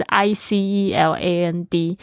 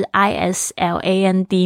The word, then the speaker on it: island: Twenty three Denmark 丹麦，Twenty four Iceland 冰岛，Twenty five 哦，对不起，这边大家特别注意一下，Iceland 它是 I C E L A N D，但是你在讲这个岛，有很多同学那个岛，我那个岛屿是也会念成 Iceland，不对哦，岛屿那个拼法是 I S L A N D 没错，但是它那个 S 是没有发音的，所以呢，岛屿是 Island。不要跟 Iceland 混在一起哦。